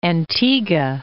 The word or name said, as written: Antigua